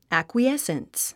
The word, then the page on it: acquiescence: æ`kwiésns; アクイエッスンス